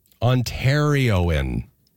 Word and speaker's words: Ontarioan: Ontarioan is how you say that word.